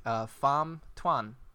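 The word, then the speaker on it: Phạm Tuân: Pham Thuan, to go into space. I did confirm that's how you pronounce it.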